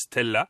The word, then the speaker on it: STLA: et sous la carrosserie, sous, sous tout le véhicule, se trouve une plateforme STLA qui se prononce Stella.